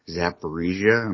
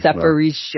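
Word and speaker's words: Zaporizhzhia: It's at Zaporizhia. Am I pronouncing that correctly? – Mm-hmm. – Okay. – Zaporizhia.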